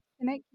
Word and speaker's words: Sinek: Sinek, yeah.